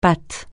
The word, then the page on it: patte: francais